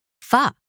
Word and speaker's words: pho: I made pho. Oh. Yeah. And you said it right.